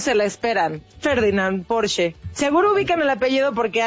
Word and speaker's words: Porsche: Y solo como un dato cultural, déjenme les cuento que es alemana y su correcta pronunciación es, escuchen, Porsche.